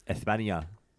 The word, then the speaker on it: España: They say, because the actual place is pronounced España. So.